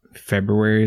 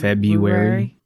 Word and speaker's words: February: It's like February is another one. I don't mind February. Like, yeah. – Just a silent R for the first one.